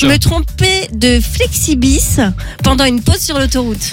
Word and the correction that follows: Flixbus: Alors, est-ce qu'on est sûr de la prononciation du bus ? Comment t'appelles ça, toi ? T'appelles ça comment ? – Un Flexibis. – Un Flexibis. – Je crois que c'est un Flixbus.